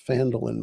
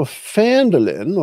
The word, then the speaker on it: Phandalin: So I'm going to go next to switch to our, see if I can find the right Phandalin map here. So we can share that one. So Phandalin or Phandalin, you can pronounce it either way. We're very flexible here.